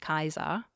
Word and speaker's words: Caesar: Similarly with Caesar, his name is not pronounced Caesar, it's pronounced Kaiser.